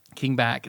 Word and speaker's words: Bach: King Back. – It's spelled like Bach. But he says it like back.